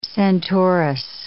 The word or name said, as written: Centaurus